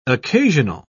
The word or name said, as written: occasional